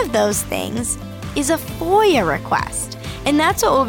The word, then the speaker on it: FOIA: FOIA stands for Freedom of Information Act and is pronounced FOIA.